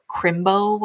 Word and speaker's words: Chrimbo: And I had no idea what Crimbo was. And it's spelled CHRIMBO, and I had to Google it.